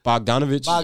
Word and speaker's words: Bogdanovic: How you say his name? Bogdanovic? – Bogdanovic.